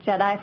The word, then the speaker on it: JEDI: And yes, you did pronounce it correctly. It has nothing to do with Star Wars, but it is pronounced JEDI for Jobs and Economic Development Impacts.